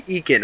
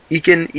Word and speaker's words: Eakin: And maybe the more one of the more underrated moves is getting Cody Eakin. Am I saying his name right? You know, Eakin, E-A-K-I-N? Yeah, I think so, but I don't know enough about him to comment on that one.